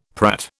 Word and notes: prat: British English

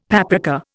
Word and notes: paprika: Indian

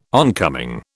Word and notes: oncoming: British English

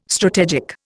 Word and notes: strategic: Indian

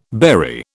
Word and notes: bury: British English

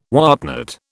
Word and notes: whatnot: British English

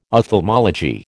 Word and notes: ophthalmology: American English

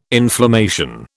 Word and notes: inflammation: British English

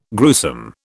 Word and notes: gruesome: British English

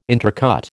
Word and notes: intercut: American English

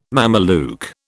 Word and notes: Mameluke: British English

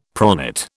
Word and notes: pronate: British English

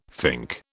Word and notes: fink: British English

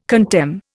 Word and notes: contemn: Indian

